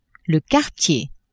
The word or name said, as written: quartier